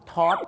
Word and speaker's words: taught: teach taught นั่นเองครับออกเสียงเหมือนคุณ taught ทองดีนะฮะฝรั่งชื่อดังนะครับที่มาเมืองไทยมาหลาย 10ปีแล้วนะครับไม่ใช่ teach taught นะครับ